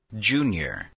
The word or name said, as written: junior